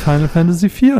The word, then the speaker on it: Final Fantasy IV: Und ich liebe die Musik aus Final Fantasy IV und es ist so toll und ich liebe sie alle.